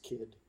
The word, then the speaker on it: Cid: C-I-D. – It's pronounced Kid. – Kid Swank? – Kid Swank. It's a hard C.